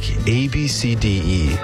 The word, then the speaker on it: ABCDE: The one that had people doing a double take, ABCDE. Yep, spelled ABCDE, but pronounced Ab-C-D.